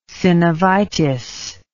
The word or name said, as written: synovitis